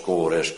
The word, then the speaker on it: Cyrus: Maar je weet inmiddels ook dat er een figuur verschenen is onder de naam die Cyrus, wat je heel gemakkelijk als Kores kunt uitspreken, onder de CK is en andersom. En die Y van Cyrus, ja, die wordt uitgesproken met een als op door de Comri. Die je schrijft als Kimri. En het wordt uitgesproken als een U. Door alle Duitsers en Denen in elk geval. Meer weet ik niet. Zodra er een Y staat, zeggen zij U. Dus Kores en Cyrus, ja, dat is heus precies hetzelfde.